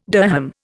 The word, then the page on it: dirham: Indian